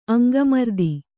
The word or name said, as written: ಅಂಗಮರ್ದಿ